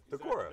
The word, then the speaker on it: Decorah: Decorah, Iowa. – Oh yeah. Decorah. – Did I say that right? – Yeah, absolutely.